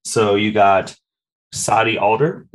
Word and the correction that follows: Sadie Adler: Sadie Adler.